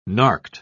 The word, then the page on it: narked: British English